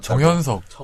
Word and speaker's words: 정현석: 그리고 정 당신이 발음 이상한 거 누가 발음 이상한 거면 날왜 다 전현석으로 알았다. – 정현석. 정현석, 정현석. – 이응을 좀 가게 줘요 정현석. 정현석.